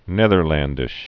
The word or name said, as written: Netherlandish